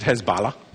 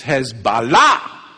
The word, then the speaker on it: Hezbollah: They don't want you to pronounce it Hezbollah. Mm-mm. It's Hezbollah. Allah.